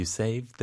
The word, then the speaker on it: saved: You saved the day. – You saved the day. – 끝부분을 약간 짧게 스톱하면 돼요.